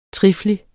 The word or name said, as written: trifli